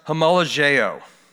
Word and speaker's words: homologeo: The word confess in the Greek is pronounced homologeo. Homologeo.